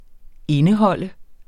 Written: indeholde

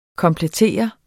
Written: komplettere

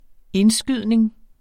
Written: indskydning

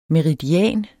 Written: MERIDIANEN

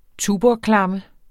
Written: tuborgklamme

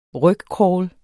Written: rygcrawl

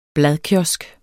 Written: bladkiosk

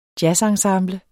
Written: jazzensemble

